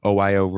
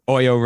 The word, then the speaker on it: OYO: The first one is, and correct me if I'm pronouncing any of these names wrong, Ritesh Agarwal of OYO Rooms, right? – Yep. – So yep, OYO Rooms. Yep. – OYO Rooms. Mm-hmm.